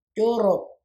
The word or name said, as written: joorroob